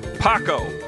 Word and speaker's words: Paco: I have a lot more words that are Pac. Paco, guys. – P-A-C-O. Yeah. It's a proper name in Spanish. – See if I've affected JD. Yeah, I know he wasn't pronouncing it Paco.